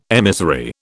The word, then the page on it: emissary: British English